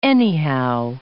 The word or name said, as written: anyhow